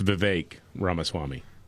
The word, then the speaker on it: Vivek Ramaswamy: It's Vivek Ramaswamy.